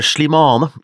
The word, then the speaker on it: Slimane: Dette er altså en artist som heter eh, Shalima. Um, akkurat hvordan du uttaler det er jeg litt sånn usikker på. Uh, Slimane. Slimane, mener jeg.